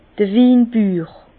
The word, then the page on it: d'r Winbüür: Bas Rhin; Herrlisheim